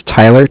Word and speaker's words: Tyler: My name is Tyler, T-Y-L-E-R, and I am I'm calling with Cardiff. It's spelled C-A-R-D-I-F-F, as in Frank Frank.